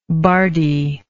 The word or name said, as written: bardiness